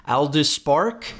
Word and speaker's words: Aldous Spark: Aldous Spark.